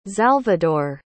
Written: xalvador

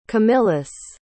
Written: camillus

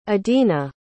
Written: idina

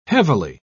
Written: heavily

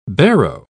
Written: barrow

